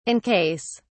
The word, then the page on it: in case: British English